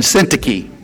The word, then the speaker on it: Syntyche: But this is how you say it. Euodia and Syntyche.